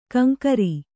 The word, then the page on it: ಕಿಂಕರ: kannada